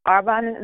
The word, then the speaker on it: Arbonne: You mentioned, and I forgive me because I completely mispronounced it earlier, but you mentioned how you work with Arbonne, if I'm pronouncing it right? – Yes.